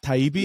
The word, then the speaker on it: Taibbi: I was listening this morning on the way into the office was a podcast with and I, I don't really know how to pronounce his last name, uh, Matt Taibbi. – Yeah, you got it right.